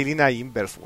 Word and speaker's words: Lina Inverse: Es como el caso de Rina y Lina Inverse, weón. – Sí, weón. – ¿Es Rina o Lina? – Se escribe Rina Inverse, Rina porque, pero se dice Lina Inverse.